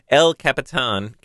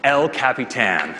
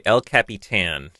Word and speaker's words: El Capitan: Why did I say it that way? El Capitan. Now I don't know how to pronounce it properly. El Capitan. Anybody? Craig? – OS 10 El Capitan. – Okay, El Capitan.